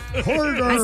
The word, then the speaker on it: Hooters: Se dice Hooters. – Hoy no más. – ¿No? Bueno, pues sí, porque la H es muda.